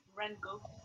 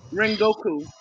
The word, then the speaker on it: Rengoku: Rengoku. – Yeah, Rengoku.